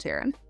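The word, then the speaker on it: Tairn: Only the rider can pronounce his full name. – Yeah. Exactly. That's exactly why we pronounce it Taren.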